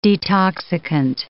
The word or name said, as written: detoxicant